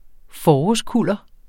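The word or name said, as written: forårskuller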